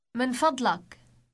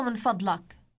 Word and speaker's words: من فضلك: Have you noticed the uk ending? Now request the man to write it down. – اكتبه من فضلك. اكتبه من فضلك. – How do you say please to a woman? – من فضلك. من فضلك. – Note the ik ending.